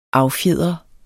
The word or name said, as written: affjedre